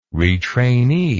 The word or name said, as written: retrainee